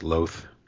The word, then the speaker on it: loath: And it's pronounced to rhyme with both, loath and both.